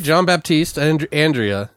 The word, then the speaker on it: Jean-Baptiste Andrea: I think I might have said that wrong. Sorry. – No, you're right. Jean-Baptiste Andrea.